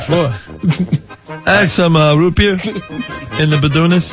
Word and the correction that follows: Bedouins: I may have been pronouncing that incorrectly. – I would say probably. – Raid in the... – It's like Bedouins? – Bedouins.